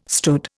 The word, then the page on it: stood: Indian